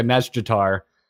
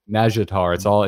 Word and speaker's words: Nazjatar: So it's kind of driving me nutty because I'm hearing all the crazy Naga ladies say it, and they do pronounce the J, but extremely quickly and more quickly than I can make my mouth work. – Yeah. – So they do say like a Nazjatar, but I can't do that. Yeah. So I just say Nazjatar. It's just like a three syllable word, and I'm done.